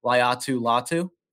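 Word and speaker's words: Laiatu Latu: I don't know exactly how to say this name, Dibes can correct me, Liatu Latu. – Yeah, that's it.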